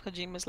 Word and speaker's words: Kojima: In light of old boys, Kajumis, Kajamis, Whatever. Kojima. It's called Jima, Sheree.